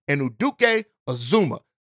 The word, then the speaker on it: Anudike-Uzomah: Felix Anuduke Uzuma. And I listen, from somebody who mispronounces names all the time, I'm going to say it again because I got it right. Felix Anuduke Uzuma.